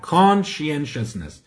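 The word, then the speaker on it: conscientiousness: میگن چرا اینو غلط تلفظ میکنی نه کانشینشسنس این با کانشسنس فرق میکنه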